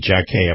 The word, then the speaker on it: Jacquie: And I think I said that right. J-A-C-Q-U-I-E. Jack A, I believe that's where you pronounce that.